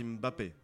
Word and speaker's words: Mbappé: D'ailleurs, Mbappé devrait prendre note. – Oui, exactement. Arrêtez de vous prononcer contre le racisme. – D'ailleurs, voilà. – On dit Mbappé.